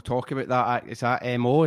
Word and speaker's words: MØ: In the West, like in UK and in America, it's Mo. Like, that's the way she'll tell folk to say it. In Danish, it's like M with O with a line through it. But in Danish, you call that, it's pronounced Moo. But then if I was to say that, you'd say Moo, and it's like, no, the Danes are like, no, that's the sound a cow makes.